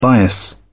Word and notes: bias: British English

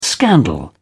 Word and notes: scandal: British English

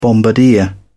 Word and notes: bombardier: British English

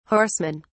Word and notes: horsemen: British English

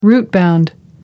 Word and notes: rootbound: American English

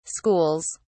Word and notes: schools: British English

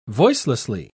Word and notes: voicelessly: British English

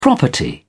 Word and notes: property: British English

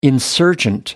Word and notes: insurgent: American English

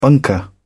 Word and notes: bunker: British English

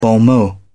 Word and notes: bon mot: British English

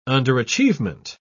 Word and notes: underachievement: British English

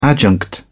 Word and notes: adjunct: British English

